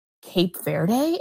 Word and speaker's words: Cape Verde: I do think that Cape Verde, am I pronouncing that right? – Mm-hmm.